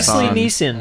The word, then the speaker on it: Nielsen: Like the Nielsen right? Yeah, it's Leslie Nielsen.